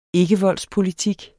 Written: ikkevoldspolitik

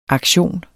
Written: aktion

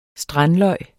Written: strandløg

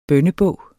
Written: bønnebog

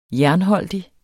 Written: jernholdig

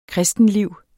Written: kristenliv

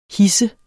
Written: hidse

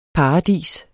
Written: paradis

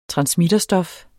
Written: transmitterstof

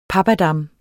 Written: pappadam

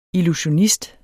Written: illusionist

